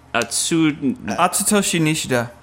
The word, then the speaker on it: Atsutoshi Nishida: Atsutoshi Nishida.